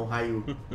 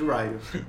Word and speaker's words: Ryu: Porque os americanos nunca aprenderam direito que Ryu você fala Ryu e não Ryu. Inclusive tem gente que chama o Ryu do Street Fighter de Ryu.